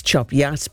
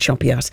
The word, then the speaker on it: Chop Gate: And I said, as far as I know, it's Chop Yat, but it's obviously spelt Chop Gate, but you pronounce it Chop Yat.